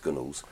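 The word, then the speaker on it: Gunwales: and I will say that Gunnels is spelt G-U-N Wales, but pronounced Gunnels.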